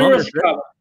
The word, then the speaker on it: Piracicaba: É, peraí, peraí, você está falando errado o nome da cidade. – É Qual é Piracicaba.